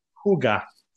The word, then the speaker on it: hygge: On Iggy, on l'écrit H-Y-G-G-E, mais on le prononce Huga.